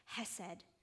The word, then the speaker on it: hesed: I'm going to butcher the Hebrew here, but the word for that is hesed.